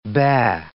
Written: baa

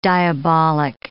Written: diabolic

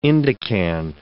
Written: indican

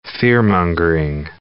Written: fearmongering

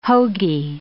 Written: hoagy

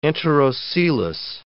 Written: enterocoelous

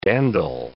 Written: dandle

